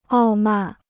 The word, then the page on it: 傲慢: ào màn